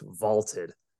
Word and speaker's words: VLTED: That's VLTED, pronounced Vaulted.